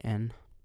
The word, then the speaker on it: Liban: I didn't really do that, but hi, my name's Liban. I don't know how to pronounce that in English at all. It's Liban.